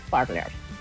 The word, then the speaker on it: Parler: Pero bueno, estuve investigando la pronunciación según es Parler.